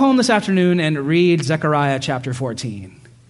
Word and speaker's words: Zechariah: My rabbi colleague pronounces it Zechariah, which sounds way cooler than Zechariah, doesn't it?